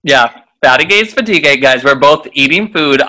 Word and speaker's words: fatigué: So she would say fatigué, which is how you say it. But obviously when you're little, you hear fatty gay.